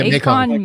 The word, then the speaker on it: Mekong: Specifically the Mekong, is that how you say it? – Yeah, Mekong Maiden and the Kawango Kate.